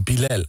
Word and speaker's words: Bilal: Want het is Bilal. Het is Bilal. Oh. Mensen zeggen Bilal, ja. Maar het is Bilal. – Bilal. – Bilal. – Bilal. – Ja, dus mensen kunnen het niet zo goed uitspreken.